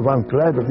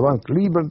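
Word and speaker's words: Van Cliburn: И вот, пока еще Москва узнала на первом туре, кто такой Ван Клайберн, я произношу так, как мы произносили тогда эту фамилию. Есть варианты американских, которых я просто не знаю. Ван Клайберн, там по-разному говорят.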